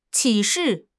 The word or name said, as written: 启事